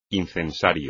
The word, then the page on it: incensario: espagnol